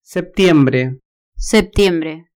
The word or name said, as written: septiembre